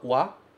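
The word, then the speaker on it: wa (は): Você tem a partícula wa, que se escreve ha, e você tem a partícula e, que se escreve re.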